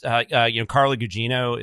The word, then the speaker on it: Carla Gugino: And it's just, Carla Gugino is that her name? – Yeah, that's how I pronounce okay.